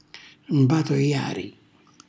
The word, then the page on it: mbatojari: Calabrese